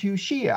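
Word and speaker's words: Yeshua: and he said, well, the man's name is pronounced Yushia.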